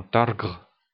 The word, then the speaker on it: targ: C'est la source principale d'alimentation chez les klingons et ils se mangent de préférence vivants les targ, qui se prononcent en klingon targre.